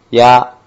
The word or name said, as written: ya